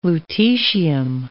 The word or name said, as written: lutetium